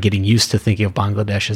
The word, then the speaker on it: Bangladesh: So it's funny, when you hear it pronounced at the time, it's typically spoken as two words, Bangladesh. And that's like the way George says it in the song, it's saying Bengal nation.